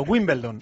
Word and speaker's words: Wimbledon: Wimbledon, tú olvídate de la L, la L va detrás de la E, para pronunciarlo, Wimbledon.